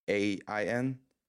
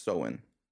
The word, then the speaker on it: Samhain: It's spelled like really weird. It's spelled Samhain, but it's pronounced Samhain.